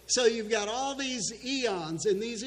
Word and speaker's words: aeons: So the way they constructed their heresy, their false doctrine, is they had these things called, I think they're pronounced eons, A-E-O-N-S.